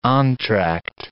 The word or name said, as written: entr'acte